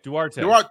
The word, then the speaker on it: Duarte: That block shot he had on is it Durant? I can't pronounce his name. Is it Durante? Yeah. Am I saying it right? – Duarte.